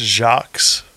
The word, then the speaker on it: Jacques: Is it Jacques or Jacques? I think it's just Jacques.